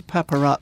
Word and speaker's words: paparazzi: It's something to do with the paparazzi. – Well, have I said that correctly? – You have indeed, yeah.